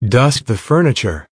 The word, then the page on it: dust the furniture: American English